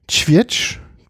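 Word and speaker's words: Żywiec: Also ich hätte Zivietsch ausgesprochen. – Zivietsch, oder Zivietsch. – Du musst genau. Ja vorne betonen. – Ja, stimmt, Zivietsch. Du hast die vorletzte Silbe.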